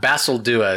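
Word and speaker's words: Basaldua: Basildua is the way you pronounce his name.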